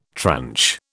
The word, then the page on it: tranche: British English